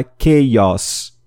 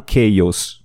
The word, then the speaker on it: chaos: Eu quero chamar atenção aqui para a pronúncia da palavra caos, que em inglês tem o som aberto no final do O. Então é chaos e não chaos, ok? Chaos.